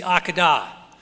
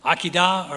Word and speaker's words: Akedah: It's known as the Akedah. And I've looked up the pronunciation of the Hebrew, and as with most things, one word, four pronunciations for the Jews. Akedah or Akedah, I'll call it the Akedah.